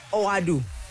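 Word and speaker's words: Ouaddou: Abdeslam Oradu. Oadu. Am I saying that right? Abdeslam Oadu. – That's it.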